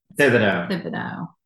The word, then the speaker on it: Thibodeau: Thibodeau. – Thibodeau. So I threw in an extra syllable in there. I was like, Thibodeau.